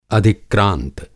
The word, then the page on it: अधिक्रांत: Hindi